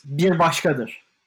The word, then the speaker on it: Bir Başkadır: No, I'm not going to pronounce it. Please pronounce it. – It's Bir Başkadır.